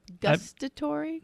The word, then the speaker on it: gustatory: Do any of these, I mean, sight, auditory, taste, because I can't say that word, gustatory. – Can we see it? – Yeah, right there in the middle. Am I saying that right? – Good enough.